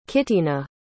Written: Kitena

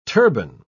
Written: turban

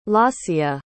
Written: laasya

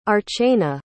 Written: archana

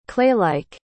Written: claylike

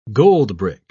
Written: goldbrick

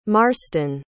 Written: marsten

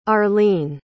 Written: arline

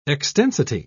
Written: extensity

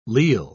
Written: leal

